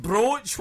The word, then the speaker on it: brooch: No, a badge, no, it rhymes with scroach. – Thingamajiggy. – You can only say thingamajiggy. Scroach, I was trying. What are you laughing at? I was trying to describe brooch.